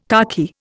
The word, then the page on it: khaki: Indian